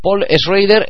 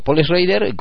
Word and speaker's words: Paul Schrader: Paul Schrader es un director y guionista al que yo le llamo Paul Schrader, Gonzalo Arado en el equipo B le llama Paul Schrader, y en cualquier caso, en español sería muy difícil de pronunciar, porque es Paul S.C.H. Rader. Un nombre complicado, pero un guionista magnífico.